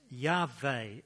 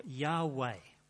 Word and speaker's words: Yahweh: It's usually pronounced Yahweh or Yahweh and is sometimes transliterated into English as Jehovah.